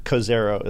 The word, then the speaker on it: Cozairo: So before I should let you know, Cozairo is how it's pronounced, okay.